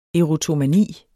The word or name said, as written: erotomani